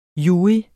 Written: jurys

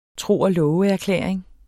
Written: tro

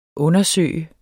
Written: undersøge

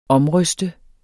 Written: OMRYSTEDES

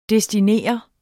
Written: DESTINER

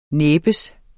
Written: næbbes

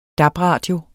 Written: DAB-radio

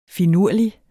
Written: finurlig

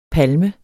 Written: palme

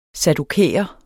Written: saddukæer

